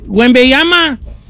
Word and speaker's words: Wembanyama: Don Cheto, a ver, dígame, ¿cómo se dice? – Wembanyama.